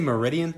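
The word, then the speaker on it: Meriden: I did I say Meridian? Is that am I saying that right? Yeah, it's M E R I D E N, right? – Yeah, that's where she's at.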